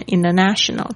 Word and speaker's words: international: International, 有些人他念很快的时候会念 international, 这边是 T 的这个发音的一个潜规则，就是在 N 后面它会有变音的现象。